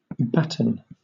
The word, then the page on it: batten: British English